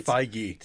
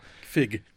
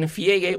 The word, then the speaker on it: Feige: Am I pronouncing that right? – Feige. – See, I was going with you. – Let's never pronounce it right. Let's just never pronounce it right. – Thank you. – Feige. – I like that very much so. – Fig. – She asked Kevin Feige, what about a movie with female superheroes? – No, it really is Feige.